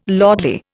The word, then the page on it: lordly: Indian